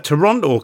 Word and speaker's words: Toronto: I'll just say that we Torontonians wince whenever people pronounce the second T. It would be like someone saying Newcastle. Fair enough. If you want to learn yourself Canadian, you have to say Toronto or Toronto, eh?